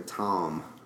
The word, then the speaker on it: Thom: Make sure you call the one and only Erika Thom.